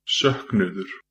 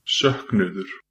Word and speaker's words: Söknuður: It's S-O with an umlaut, K-N-U, O with a thing above it that I don't even know what it is, another U and an R, okay? And it's pronounced Söknuður.